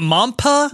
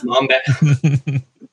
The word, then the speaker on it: Mamba: Is it Mampa? – Mamba.